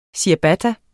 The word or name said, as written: Ciabatta